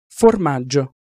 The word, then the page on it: formaggio: Italian